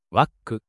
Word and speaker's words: WACC: At our WACC of around 8%, the project still clears our hurdle rate. – Clears our hurdle rate, hurdle rate を超える。この、 clear が投資判断の定番動詞です。基準を超える、クリアする。 WACC は、 WACC と読む。